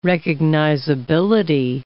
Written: recognizability

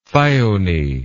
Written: thyone

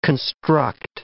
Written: construct

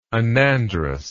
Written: anandrous

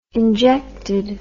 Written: injected

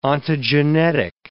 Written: ontogenetic

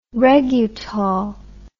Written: regutol

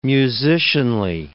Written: musicianly

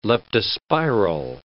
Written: leptospiral

